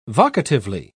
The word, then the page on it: vocatively: British English